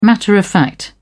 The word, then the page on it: matter-of-fact: British English